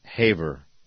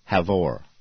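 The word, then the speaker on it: Havre: and I pronounced it Haver. And we had a listener email me in and say, love your show, but it's not Haver, it's Havor.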